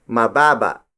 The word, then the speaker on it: mababa: If an object isn't tall enough, we say mababa. Mababa. Mababa. Mababa is spelled as M-A-B-A-B-A.